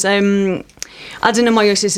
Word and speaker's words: adenomyosis: Why adenomyosis? Have I said that correctly? – Adenomyosis. You can just call it adenome.